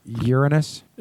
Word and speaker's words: Uranus: NASA wants your help studying Uranus from behind next month. – It's actually pronounced Uranus.